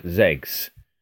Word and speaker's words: XEGS: So I tend to say the word ZEGS.